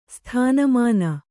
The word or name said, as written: ಸ್ಥಾನಮಾನ